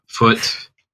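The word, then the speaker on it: Foote: My name is Nate, Foot, like with an E at the end, but it's pronounced like the one on your leg.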